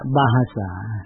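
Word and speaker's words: bahasa: อ่านว่าบาฮาสาคนอินโดนีเซียเรียกภาษาของเขาว่าบาฮาสาอินโดนีเซียคนมาเลเซียก็เรียกภาษาของเขาว่าบาฮาสาม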